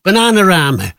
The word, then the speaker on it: Bananarama: Bananarama, or you give it the correct Scouse pronunciation, Bananarama.